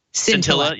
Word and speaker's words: scintilla: which is more than a scalinta. I think that's how – I always screw that word up. – Scintilla. – Yes.